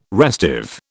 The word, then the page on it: restive: British English